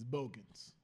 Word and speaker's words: Bogans: Oh, Bogans. Okay, I thought it was Bogus. – It's Bogans. – Keith Bogans. – How you spell that? B-O-G-U-S? – You know how to spell it, nigga.